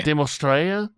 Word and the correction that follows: Dimitrescu: No, Lady Dimitrescu.